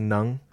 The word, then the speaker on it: Ng: I-N-G? – N-G. But it's pronounced Ng. – Nung or? – Nga? – No. He's a Vietnamese. – Yes. – I think. – He's a Viet? – It's just Nung.